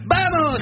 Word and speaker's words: Vamos: Oui, ça s'écrit Vamos, mais ça se dit Vamos. En Espagne le V se dit B, mais ça c'est limite.